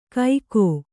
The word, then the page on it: ಕೈಕೋ: kaikō